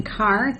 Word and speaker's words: Carr: Yes, my name is Joanne Carr. That's spelled J-O-A-N-N-C-A-R-R.